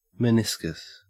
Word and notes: meniscus: Australian English

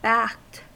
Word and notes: backed: American English

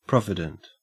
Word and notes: provident: Australian English

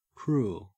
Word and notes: cruel: Australian English

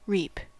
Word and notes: reap: American English

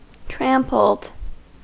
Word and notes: trampled: American English

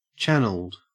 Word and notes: channeled: Australian English

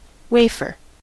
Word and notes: wafer: American English

